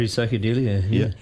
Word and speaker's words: Recycadelia: So Tohuhu has actually been going for quite a while, really, but you've just released your debut, Recycadelia. Have I said it right? – Yep. Recycadelia, yeah.